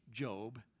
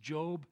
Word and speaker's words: Job: Now if you think you have been through a series of trials, read Job. It looks like it should be pronounced Job. I don't know why it's not. Should have an E on there. It's Job.